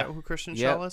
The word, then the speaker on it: Kristen Schaal: Kristen Schaal, I think.